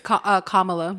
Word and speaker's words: Kamala: How do you pronounce it? – It's Kamala.